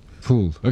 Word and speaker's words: Proulx: It's a very long book, which is Barkskins by Annie Proulx. – Proulx. – Oh, so no, I stand down though for this one. I thought it was Proulx. – Proulx. Okay. Anyway, we know what we're talking about.